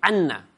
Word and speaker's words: Anna: Pero porque es que se pronuncia así, Anna. – Si lo pronuncias bien, se pronuncia Anna, igual que Gemma con dos M's, en catalán es Gemma.